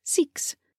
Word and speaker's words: Seaques: You can find Mirror Secret Mirror by Jessica Seaques, pronounced Seeks, but spelled S E A Q U E S on Amazon, Apple, and all the major ebook retailers.